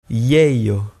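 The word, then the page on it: ieio: Provençal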